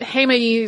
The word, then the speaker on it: Heimaey: Heads up that every piece of non-Icelandic video footage I have seen about this eruption pronounces it in some other way, like Heimaey or Heimaey or Heimaey, but Heimaey is closest to how I've heard people actually pronouncing it while I was in Iceland.